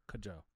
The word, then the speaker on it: Kwadwo: All right, how do you say it? – Kajo. – Kajo. I mean, K-W-A-D-W-O. Kajo.